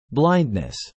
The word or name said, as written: blindness